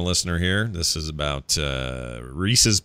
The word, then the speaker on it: Reese's: R-I-S-I is the spelling. It's Italian. The candy is Reese's Pieces, more like the name Reese.